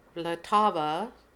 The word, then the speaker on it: Vltava: and I found it very interesting because it's Vltava is the Czech way of pronouncing this.